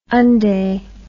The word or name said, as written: undee